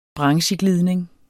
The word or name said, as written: brancheglidning